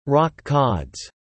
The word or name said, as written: rockcods